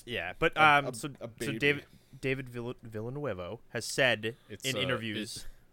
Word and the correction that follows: Villeneuve: I googled it and had them pronounce it for me. It's Villeneuve.